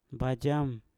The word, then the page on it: bajam: Mpur